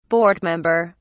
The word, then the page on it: board member: British English